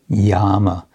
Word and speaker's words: yama: And the first of these is called yama, uh, which means, or it can also be with a long A, yama.